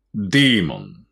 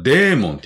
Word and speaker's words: demon: demon。日本ではデーモンって言いますけど、発音はディーモンですね。